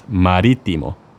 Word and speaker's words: marítimo: We also have an accent on the first I of marítimo. When you see a dash mark above a letter in Spanish, that means we stress that letter.